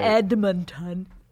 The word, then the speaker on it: Edmonton: Edmonton. No, that's how people say it.